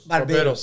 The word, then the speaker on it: barbeiros: From where you started, from doing a barber to Barbosos? That's how you say it? – Barbeiros. – Sorry. – Barbeiros.